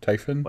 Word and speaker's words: Typhon: You think that's right, Typhon? Am I saying that right? – Yeah, it's like Tyson, but with a PH. Typhon? – Like the chicken?